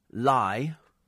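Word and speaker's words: Leigh: Also two Lees, one in Manchester, one in Kent, but pronounced Lye. Pronounced Lye, but it's spelt Lee.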